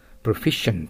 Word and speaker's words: proficient: これをプロフィシエントと呼んでしまっては、リスニングの時にアウトですよ。 proficient と発音してください。 proficient、 sufficient、 efficient 知ってますね。あれと同じですね。